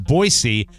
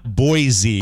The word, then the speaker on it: Boise: It's pronounced Boise, not Boise.